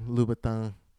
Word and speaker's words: Louboutin: I also, like a little nuance, Jasmine pronouncing Louboutin, but pronouncing the S, was just like a little detail. I'm like, well, you wouldn't say the S.